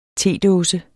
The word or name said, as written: tedåse